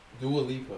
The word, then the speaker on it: Dua Lipa: Dua Lipa. – Is that Lipa? – No. – Oh, Dua Lipa song.